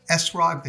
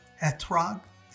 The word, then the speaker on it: etrog: The waving of the lulav and the ethrog or esrog, which more uh, Ashkenazic uh, European uh, Jewish people uh, use the word esrog, they pronounce it the same Hebrew letter as an S, and in modern Hebrew it's a T, etrog.